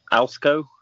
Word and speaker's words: ALSCO: And then in the ALSCO, I guess is how you pronounce that. – Yep.